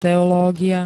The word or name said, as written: teológia